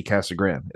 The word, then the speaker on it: Casa Grande: And I know Casa Grande isn't how you pronounce it, but that's how everyone fucking pronounces it here. It's weird. Like, obviously it's like Casa Grande, right? But everyone calls this city Casa Grande. It's weird. Even Mexicans call it just Casa Grande.